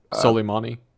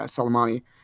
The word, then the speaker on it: Soleimani: Soleimani. – Yeah, Soleimani.